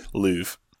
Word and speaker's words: Louvre: Louvre.